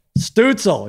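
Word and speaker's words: Stützle: The Stutzel. – Late, great Stutzel, he says. So there you go. That's apparently how you say it.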